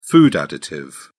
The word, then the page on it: food additive: British English